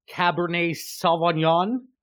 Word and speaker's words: Cabernet Sauvignon: So I fully intend on finishing this nice Cabernet Sauvignon.